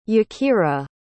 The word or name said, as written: yakira